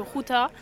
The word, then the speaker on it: Gouda: Ça a été inventé à Ruta, la même ville où le fromage Gouda a été inventé, mais le G aux Pays-Bas se prononce R, donc euh, c'est le Ruta.